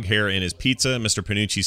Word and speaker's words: Panucci: In the episode Jurassic Bark, they find Fry's dog Seymour in a museum exhibit in Panunzi's Pizza, where Fry used to work. Am I saying that right? I probably remember. – Panucci. – Is it Panucci? Yeah, Panucci's Pizza.